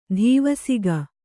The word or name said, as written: ಧೀವಸಿಗ